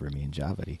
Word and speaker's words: Ramin Djawadi: My first one is, first off, a little sub memory is that Ramin Djawadi's name is pronounced Ramin Djawadi.